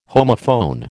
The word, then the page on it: homophone: American English